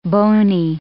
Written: boney

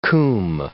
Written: coombe

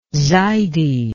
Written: zaydi

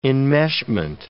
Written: enmeshment

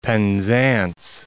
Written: Penzance

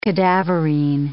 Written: cadaverine

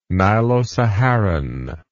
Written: Nilo Saharan

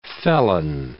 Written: felon

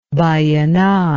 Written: Vallenar

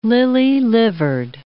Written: lily-livered

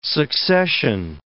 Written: succession

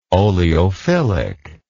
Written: oleophilic

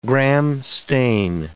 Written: Gram stain

